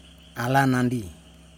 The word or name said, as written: alanandi